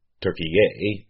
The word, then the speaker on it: Türkiye: Türkiye is how the country's name is pronounced in Turkish.